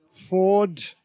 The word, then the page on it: ford: British English